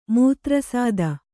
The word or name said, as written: ಮೂತ್ರಸಾದ